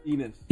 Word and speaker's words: Enes: Well, uh, I guess you owe, what's how you pronounce his name? Inez? – Enes. – Enes Kanter.